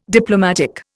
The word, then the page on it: Diplomatic: Indian